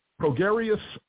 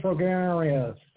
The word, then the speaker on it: Prograis: He says, Progarius Spence. What is Progarius? Yeah, Progarius Spence. – That's how Spence pronounces it. Regis Prograis. That's how he said it.